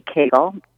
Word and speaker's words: Kegel: You have to be able to identify what it feels like to do a Kegel, which rhymes with bagel, by the way.